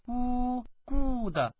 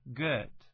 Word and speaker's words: good: 大家体会一下啊，这个发好，我们说很好 good, 请注意发音不是说呜 good, 或者发短一点 good, 而是 good, 不是简简单单的 good, 请注意是 good。